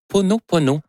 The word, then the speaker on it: Ho'oponopono: Non, c'est une philosophie hawaïenne de, je vais le prononcer comme il faut, de l'O-O. Ho'oponopono, le Ho'oponopono,